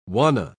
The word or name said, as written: wanna